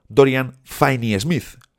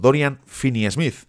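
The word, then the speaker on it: Dorian Finney-Smith: Me decíais el otro día que no es Dorian Finney Smith, sino Dorian Finney Smith. Creo que ahora lo he dicho bien.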